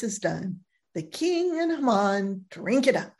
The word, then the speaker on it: Haman: And what is also interesting is that Haman, I call him Haman, Haman, whatever, it's pronounced Haman, sends the orders nearly a year in advance of the chosen date.